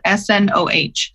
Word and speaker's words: Snoh: It's spelled S N O H, Snoh Alegra.